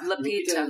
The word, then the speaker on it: Lupita: Lupita. – Lupita.